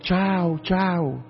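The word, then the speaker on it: ciao: Ông cha bề trên cũng cười quá trời, ông bảo không, đó là cái chữ tiếng Ý nó chiao chiao chiao á, C-I-A-O, nó phát âm là chào chào, là tiếng Ý chứ không phải là tiếng Việt Nam là chào chào đâu.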